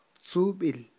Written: tzub'il

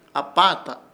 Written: APAATA

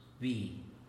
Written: bi

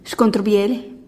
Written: xkontr bielih

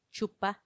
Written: chuppa

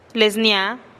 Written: les nia'a

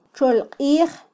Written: txolqij

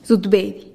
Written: zutbedih

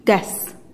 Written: ges

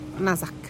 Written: nazak

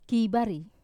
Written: kivari

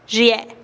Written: xiee'

